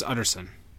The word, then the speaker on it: Utterson: But it's Utterson. But, I mean, you can mispronounce it. As Utterson. Like, you're... – Utterson? – Like, utter, complete sin.